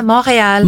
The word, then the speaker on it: Montreal: Is it that's how you pronounce it? Yeah. – Yeah, Montreal. – Montreal. – Montreal in English.